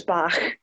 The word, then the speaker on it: Bach: So the surname is Bach, sort of pronounced like the composer. – Yeah, I'm Scottish, so it's Bach.